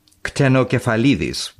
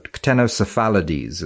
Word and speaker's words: Ctenocephalides: Ctenocephalides would be a better pronunciation, but ctenocephalides is probably the English way of saying it.